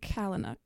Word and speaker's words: Palahniuk: Writers, and I'm going to try to say his name right, Chuck Palahniuk. – Palahniuk.